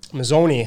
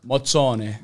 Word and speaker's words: Mozzone: Mozzone. – Mozzone. – That's the English Festa. – Way. – How do you pronounce the Italian way? – Mozzone. – Mozzone. – That's it.